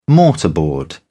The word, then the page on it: mortarboard: British English